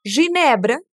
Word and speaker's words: Ginebra: Quando o G está acompanhado do E e do I, ou seja, G, G, se pronuncia igual ao J. Repete. G, G, Presta atenção nessas palavras. Ginebra, ginebra.